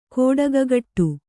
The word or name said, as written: ಗೌಟಿಕೆಗಟ್ಟು